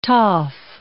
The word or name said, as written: toff